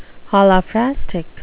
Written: holophrastic

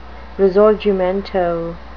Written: risorgimento

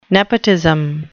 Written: nepotism

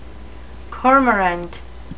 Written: cormorant

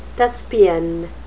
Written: thespian

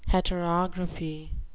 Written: heterography

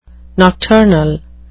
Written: nocturnal

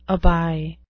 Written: aby

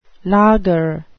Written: laager

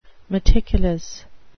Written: meticulous

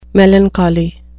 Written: melancholy

